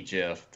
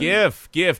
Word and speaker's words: GIF: Gif. Don't be like Mark and say gif. Gif's a peanut butter. – Yeah, GIF. It's a gif. – There is some debate over the correct pronunciation, but it is now accepted that it is a gif. I don't care if the creator said it's a gif. That's peanut butter.